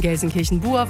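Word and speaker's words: Gelsenkirchen-Buer: Dankeschön für zahlreiche Unterstützung bei der Eselsbrücke zum Namen Gelsenkirchen-Bur, was mit UE gesprochen, geschrieben wird, aber U ausgesprochen wird.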